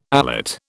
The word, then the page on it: alate: British English